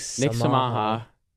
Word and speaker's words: Samha: Why does your name sound like Yamaha? – Well, it doesn't, because if you say it wrong, it does. – That's Samha, Yamaha. That's literally the same thing. – Okay, well, it's spelled the same. It still isn't my name.